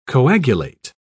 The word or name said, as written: coagulate